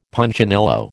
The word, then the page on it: Punchinello: American English